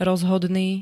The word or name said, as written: rozhodný